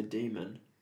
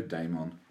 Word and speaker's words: daemon: I would say demon. – I think it's pronounced in the new show, daemon.